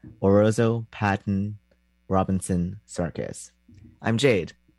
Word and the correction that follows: Orozco: It's not Orozo, it's Orozco, but I was committed to the bit.